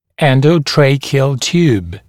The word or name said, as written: endotracheal tube